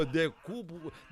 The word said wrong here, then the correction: decúbito: decubo o quê? Como é que fala? Decúbito, como é que fala? Decúbito, é negócio de... Fátima atrapalhou o negócio todinho aqui, viu? Decúbito dorsal, rapaz, decúbito dorsal.